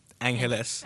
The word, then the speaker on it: Angeles: So it's spelled A-N, it's like Los Angeles, but remove the loss and it's pronounced Angeles. – Angeles. – So more Spanish, I would say.